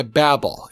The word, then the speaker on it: Babel: Some people pronounce it Journey to Babel. It's B-A-B-E-L. – Okay. – But I learned Journey to Babel because Captain Kirk says Babel.